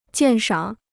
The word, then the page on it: 鉴赏: jiàn shǎng